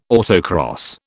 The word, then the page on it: autocross: British English